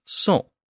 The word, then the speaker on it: cent: So Latin centum becomes C-E-N-T in French, and it's pronounced son.